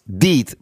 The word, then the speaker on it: DEET: Το DIT είναι DIT. DEET προφέρεται και είναι αυτό το η δραστική ουσία.